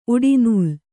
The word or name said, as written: ಉಡೆನೂಲು